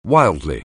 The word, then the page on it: wildly: anglais